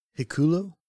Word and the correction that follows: Hikuleo: Hikalea. – Hikalea? Yeah. Okay. H I K U L E O. Yeah. Hikalea.